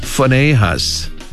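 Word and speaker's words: Phinehas: 이렇게 발음합니다. 퍼네이하스 PH로 발음을 해요.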